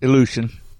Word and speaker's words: Ilyushin: Ilyushin. Is that how I say that? How do you say that? Ilyushin, right? – Ilyushin. – Ilyushin. Okay.